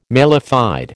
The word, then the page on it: mala fide: American English